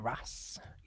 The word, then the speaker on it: wrasse: Yeah, the blue streak cleaner wrasse.